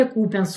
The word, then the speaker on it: Купянск: Мы тут всю жизнь называли Купянск с ударением на У.